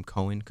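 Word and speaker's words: Coan: Is that how you say his name? Cohen. Yeah, Jack Cohen, quarterback Jack Cohen for Wisconsin.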